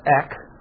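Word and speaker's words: ek (ἐκ): And it comes from a little Greek preposition that we pronounce ek, okay?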